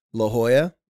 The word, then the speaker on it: La Jolla: Yeah, or in my near my hometown of San Diego, California, there's a town called La Jolla. The J is silent.